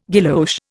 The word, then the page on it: guilloche: Indian